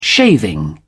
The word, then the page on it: shaving: British English